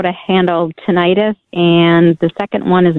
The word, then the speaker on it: tinnitus: So when it comes to tinnitus, I think is the correct pronunciation. – Okay. Thank you. – When it comes to tinnitus, you know, let's talk first from a dietary standpoint.